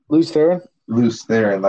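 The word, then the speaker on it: Lews Therin: Maybe this was world number 153, and Rand, the current Rand, is from, or Lewin, I'm never going to pronounce the name right. – Luce Theron.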